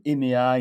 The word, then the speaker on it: HEMEA: Je rappelle que vous êtes euh, président et fondateur de EMEA. EMEA, c'est un studio digital d'architecture et de rénovation. On vous retrouve évidemment très facilement sur Internet. Ça s'écrit H-E-M-E-A.